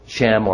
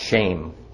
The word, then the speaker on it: Shem: They wanted to make a Shem, or more properly pronounced, shame for themselves.